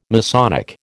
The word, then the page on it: Masonic: American English